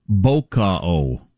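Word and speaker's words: bokeh: The word is pronounced Boca-o.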